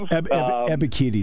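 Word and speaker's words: Ebiketie: Yes, Ebikiti, I believe. – Ebikiti is how you pronounce it.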